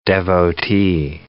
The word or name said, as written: devotee